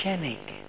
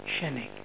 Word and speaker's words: Chenique: C-H-E-N-I-Q-U-E, how do you pronounce that name? Chenik, like unique, Chenik.